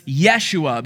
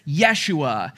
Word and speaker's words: Yeshua: In the original Hebrew, the name the angel said was Yeshua, because the Jews, they didn't have that hard J sound, so his name is Yeshua.